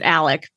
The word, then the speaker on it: Alex: I know they pronounce it Alec, but it's spelled Alex. So I know.